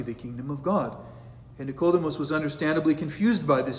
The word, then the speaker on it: Nicodemus: Words in Greek are accented, and the name Nicodemus has his accent on the second syllable.